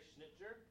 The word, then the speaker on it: Schnittjer: I've also relied to some degree on uh, Gary Schnittjer. Um, uh, I believe that's how you say his name, S C H N I T T J E R.